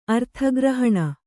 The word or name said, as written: ಅರ್ಥಗ್ರಹಣ